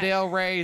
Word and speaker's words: Del Rey: Ano Del Rey is her name. – Emphasis on the Ray.